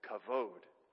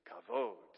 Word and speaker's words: kavod: It uses two forms of the word kavod. Can we say that word together? Kavod. Ready? One, two, three. Kavod. One more time. Ready? One, two, three. Kavod.